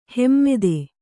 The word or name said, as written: ಹಿಮ್ಮತು